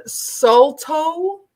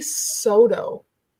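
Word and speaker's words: Saulteaux: So that word Salto is how some people say it. Yeah. It's actually Soto. – Soto. – Soto, that's a whole nation of people. There's Anishinaabe, Ojibwe, Soto.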